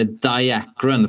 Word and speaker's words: Diakron: Eller Diakron, fast det är med K. Och då uttalar jag det svennigt av princip.